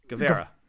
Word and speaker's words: Guevara: Guevara.